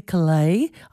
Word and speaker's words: Caillat: And counting her song in, American folk singer Colby Calais, I think the T is silent, Bubbly from 2007.